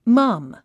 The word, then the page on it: mum: mʌ́m; マァム